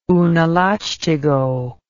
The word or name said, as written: unalachtigo